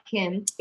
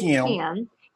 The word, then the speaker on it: Kim: So I walk out and he's like, Kim, because my name in the South is two syllables instead of one. It's not Kim, it's Kim.